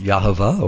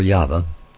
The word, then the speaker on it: Yahweh: And Yahweh is in the Hebrew Yod-Heh-Vav-Heh, and can be pronounced Yahovah or Yahweh.